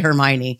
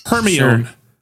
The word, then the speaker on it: Hermione: I've never heard the author pronounce it, but it's like when I watched Harry Potter, I was like, oh, that's how you say Hermione. Like it feels like one of those Hermione.